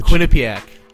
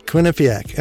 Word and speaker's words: Quinnipiac: Quinnipiac. – Quinnipiac.